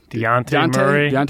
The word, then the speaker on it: Dejounte: DeJounte Murray.